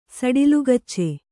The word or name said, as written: ಸಡಿಲುಗಚ್ಚೆ